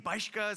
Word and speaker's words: Bischke: So Bischke is a German name, Bischke is how it's pronounced.